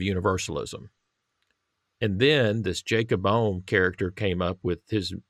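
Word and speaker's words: Böhme: And what Michael has very helpfully done is he's shown how influential Jakob Bohme is how I'd say his name. – Bohme.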